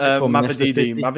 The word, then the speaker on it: Mavididi: Obviously, we had Davidi, I think that's how you pronounce it. – Mavididi.